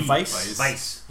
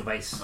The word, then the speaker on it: Weisz: Rachel Weisz. – The biggest shot. – Is that how you pronounce it? – Yeah, with a B. – Weisz. – Yeah, Rachel Weisz.